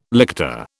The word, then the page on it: lictor: British English